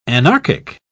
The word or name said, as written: anarchic